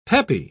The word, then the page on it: peppy: British English